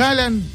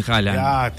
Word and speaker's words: Haaland: Viste que Haaland no se dice Haaland. – Wow, bueno. – No, los noruegos. Pero no se dice Haaland. Se dice Haaland. – Bueno, te felicito. – Haaland. Los noruegos tienen letras que no existen.